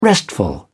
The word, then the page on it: restful: British English